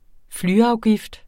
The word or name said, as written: flyafgift